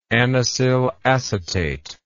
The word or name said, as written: anisyl acetate